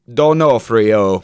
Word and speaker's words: D'Onofrio: We all know from our last episode that the proper pronunciation is D'Onofrio in the robot voice.